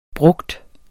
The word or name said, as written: brugt